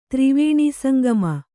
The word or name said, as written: ತ್ರಿವೇಣೀಸಂಗಮ